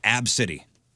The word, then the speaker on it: ABCDE: The daughter's name is A, B, C, D, E, pronounced Ab-City.